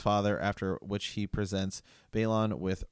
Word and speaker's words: Balon: Martin, he calls him Balon.